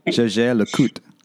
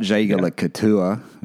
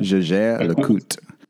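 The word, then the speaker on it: Jaeger-LeCoultre: Jaeger LeCoultre. Jaeger LeCoultre. Not Jaeger LeCoultre. That's how I used to say it. Jaeger LeCoultre.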